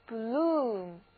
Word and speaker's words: bloom: 注意字母组合 OO 在这里要发长的 U，所以这个单词就应该念作 bloom。